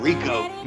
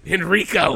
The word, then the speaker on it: Henrico: They must have recorded that in Rico.